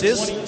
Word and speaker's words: Cisse: It's A-B-O-U-B-A-C-A-R, pronounced Abukar, and Siss is C-I-S-S-E, pronounced Siss.